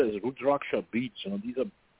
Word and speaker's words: Rudraksha: It, it's called Rudraksha. It's spelled R-U-D-R-A-K-S-H-A, Rudraksha.